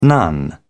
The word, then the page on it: nun: British English